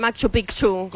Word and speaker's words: Machu Picchu: Y se dice Machu Picchu. – Machu Picchu. – Ah, porque tiene dos C.